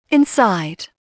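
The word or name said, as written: inside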